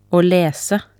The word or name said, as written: å lese